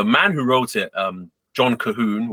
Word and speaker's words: Colquhoun: C-O-L-Q-U-H-O-U-N. Cahoon. – Yes. Yes. If you know anything about how Scots write their names and stuff, names of places, names of things, they're not always the most intuitive.